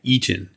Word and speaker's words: Iton: There's somebody named Isaiah Eaton, and it's spelled I-T-O-N.